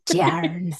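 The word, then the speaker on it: Tairn: dragon is Tern for short. That's definitely not how it's pronounced. – Tjarn.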